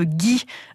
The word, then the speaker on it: ghee: Autre élément important dans la cuisine ayurvédique, c'est le ghee.